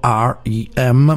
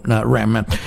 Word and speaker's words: R.E.M.: e la pronuncia inglese è lettera per lettera, ovvero R.E.M., ma in italiano viene generalmente pronunciata anche come acronimo R.E.M.